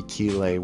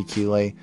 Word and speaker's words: Keelay: I believe it's called Opening the Mind of Creepy Keeley, which is my narration alter ego on YouTube. So, or you could just look up Creepy Keeley on YouTube. Creepy spelled with a K, Keeley spelled K-E-E-L-A-Y.